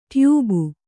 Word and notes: ಟ್ಯೂಬು: tyūbu

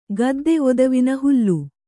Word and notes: ಗದ್ದೆ ಒದವಿನ ಹುಲ್ಲು: Kannada

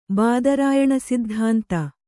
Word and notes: ಬಾದರಾಯಣಸಿದ್ಧಾಂತ: kannada

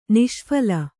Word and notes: ನಿಷ್ಫಲ: Kannada

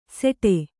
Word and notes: ಸೆಡೆ: seḍe

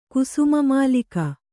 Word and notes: ಕುಸುಮಮಾಲಿಕ: Kannada

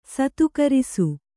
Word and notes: ಸತ್ಕರಿಸು: satkarisu

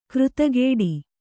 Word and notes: ಕೃತಗೇಡಿ: Kannada

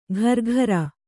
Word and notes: ಘರ್ಘರ: Kannada

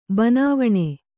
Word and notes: ಬನಾವಣೆ: banāvaṇe

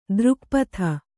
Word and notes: ತ್ರಿಗುಪ್ತಿ: tri gupti

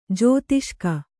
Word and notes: ಜೋತಿಷ್ಕ: jōtiṣka

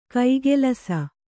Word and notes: ಕೈಗಲಸು: kaigalasu

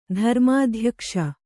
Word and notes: ಧರ್ಮಾಧ್ಯಕ್ಷ: dharmādhyakṣa

